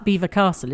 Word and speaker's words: Belvoir: I would say most people call it Belvoir. – Oh, really? – That's how it's spelled. – Okay. – It's B-E-L... – V-O-I-R. But it's like Beaver Castle, isn't it? – Well, I don't know.